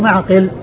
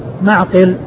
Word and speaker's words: معقل: من سواه في صحيح البخاري بهذه الصورة فهو معقل, معقل بالعين والقاف, بالعين الساكنة والقاف المكسورة.